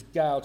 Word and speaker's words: Gaal: In verse 26, one day, Gaal, I'll pronounce him as Gaal to rhyme with Baal over in the Baal Berith, the false prophet.